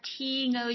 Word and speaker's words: Thyngo: T-N-O Shooting, and that's spelled T-H-Y-N-G-O Shooting on Instagram.